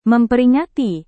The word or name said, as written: memperingati